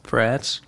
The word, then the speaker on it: Threads: Threads.